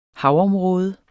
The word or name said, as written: havområde